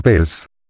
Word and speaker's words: Peirce: En inglés Peirce se pronuncia Peirce.